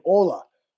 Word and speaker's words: Olga: And I had a translator, this woman named Ola, which is how she said I should pronounce what Americans would say, Olga.